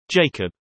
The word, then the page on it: jacob: British English